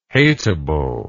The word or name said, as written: hatable